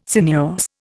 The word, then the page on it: sinuous: Indian